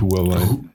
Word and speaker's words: Huawei: Huawei oder so. – Huawei. – Sagt man das so? – Eigentlich Huawei.